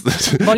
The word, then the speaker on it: Bollinger: Den spricht man nicht Bollinger aus. Bollinger? Der sieht nur so aus. Das ist Bollinger. Ja.